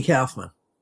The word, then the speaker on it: Kaufman: But as far as I know, the correct pronunciation, because he was also a New Yorker, was Andy Kaufman.